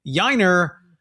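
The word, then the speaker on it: Yainer: Yiner rhymes with minor, as in leagues.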